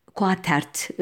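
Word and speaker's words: Quataert: Örneğin e, bu konularda çok değerli eserler vermiş Donald Quate, Quatet'e e, göre e, Quatert özür dilerim yanlış telaffuz ettim. Zorda bir yazılışı var ve Q'la yazılıyor bu da.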